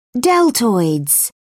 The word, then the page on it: deltoids: British English